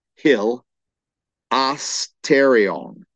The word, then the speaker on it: hilasterion: The noun, the key noun, is the word hilasterion. And how that word would be spelled is H-I-L, hil, asterion, A-S-T-A-R-I-O-N, hilasterion, H-I-L-A-S-T-A-R-I-O-N.